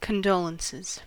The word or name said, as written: condolences